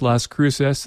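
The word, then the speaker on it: Cruxes: They spell it with an X, not a C. C-R-U-X-E-S. But it's pronounced Las Cruces.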